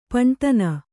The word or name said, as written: ಪೀನೋಧ್ನಿ